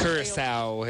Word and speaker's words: Curaçao: In fact, I feel like I'm still not saying it right. Caracho? It's got the little... – Curaçao.